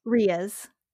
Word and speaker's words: rheas: Which are large flightless birds, which include emus and ostrich, and also kiwis, and is it rays? – Rheas.